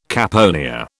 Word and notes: caponier: British English

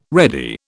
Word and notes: ready: British English

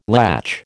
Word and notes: latch: American English

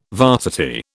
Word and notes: varsity: British English

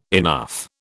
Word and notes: enough: British English

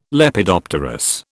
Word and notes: Lepidopterous: British English

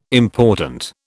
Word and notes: important: British English

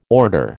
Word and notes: order: American English

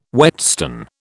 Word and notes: whetstone: British English

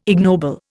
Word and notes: ignoble: Indian

